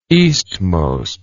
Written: eastmost

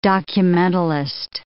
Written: documentalist

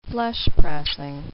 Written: flesh-pressing

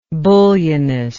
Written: bullionism

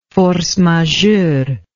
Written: force majeure

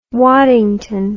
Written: Waddington

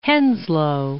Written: Henslowe

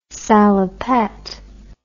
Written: salopette

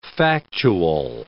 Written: factual

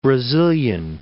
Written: Brazilian

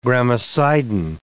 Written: gramicidin

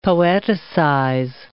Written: poeticize

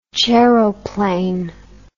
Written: chairoplane

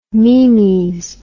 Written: meemies

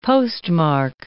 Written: postmark

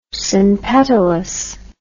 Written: synpetalous

